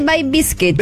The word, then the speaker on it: Bay Biscuit: By Biscuit. – Se dice Bay Biscuit. – Bay Biscuit. – Porque es como bizcocho de la bahía.